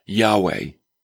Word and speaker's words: Yahweh: Well, they ascribed to him, first of all, Jehovah and then Yahweh. Okay? It's another name we don't use because we don't speak Hebrew. In Hebrew, the J is pronounced like a Y, hence Yahweh.